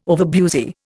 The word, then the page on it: overbusy: Indian